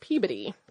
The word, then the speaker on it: Peabody: I could be mistaken since it is the museum name, but the town of Peabody is pronounced Peabody.